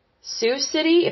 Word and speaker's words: Sioux City: The next one that failed is Vantus Bank, and that's V-A-N-T-U-S Bank. And I think it's Sioux City, if that's how you pronounce that. It's S-I-O-U-X. I don't think you pronounce the X on that word.